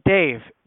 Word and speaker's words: Dave: It's Dave. D-A-V-E.